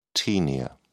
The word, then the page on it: taenia: British English